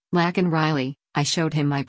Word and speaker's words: Laken: Breitbart News also asked Ogles for his take on Biden's mispronunciation of Laken Riley's name. Riley was a 22-year-old nursing student allegedly murdered by an illegal alien on February 22, and Biden pronounced her name as Lincoln on Thursday amid pressure from Republicans, many of whom wore Laken Riley pins, to address the case.